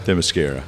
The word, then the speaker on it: Themyscira: Themyscira? – Themyscira.